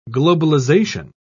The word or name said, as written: globalization